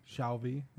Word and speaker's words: Shalvey: And this is by Declan Shavely? S-H-A-L-V-E-Y? Shavely? Shelby. I don't know. Anyway. – Shelby. Yeah. Say it like it's spelled.